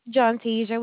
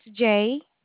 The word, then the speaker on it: Johntasia: John Tasia. Would you like for me to spell it? – Yes, please. Thank you. – It's JOHNTASIA.